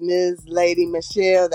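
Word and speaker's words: Mz Lady Michelle: My Spotify for Artists page is Ms. Lady Michelle.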